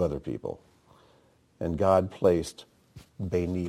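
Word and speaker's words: Beni (בני): And in between, you've got the word spelled Beit Nun Yud, which can be pronounced Beni, which means my son.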